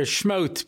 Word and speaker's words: Shmot: Anyway, so we're starting now a new Sefer Shemos, or if you have the Sephardi pronunciation, Sefer Shmot, because this is the book where God smote the Egyptians.